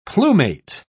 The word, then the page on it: plumate: British English